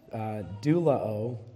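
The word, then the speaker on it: douleuō: The Greek word for served, it's pronounced doulao, and it's related to the word doulos, which literally means slave.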